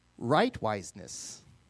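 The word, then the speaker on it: rightwiseness: Right-wiseness. Righteousness, which is in our modern vernacular, used to be spelled and pronounced right-wiseness.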